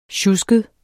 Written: sjusket

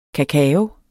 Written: kakao

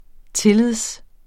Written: tillid

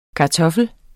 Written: kartoffel

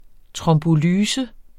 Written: trombolyse

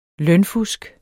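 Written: lønfusk

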